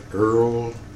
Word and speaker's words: Earl: My name is Earl Tucker Nicholson. E-Earl, E-A-R-L, Tucker, T-U-C-K-E-R, Nicholson, N-I-C-H-O-L-S-O-N.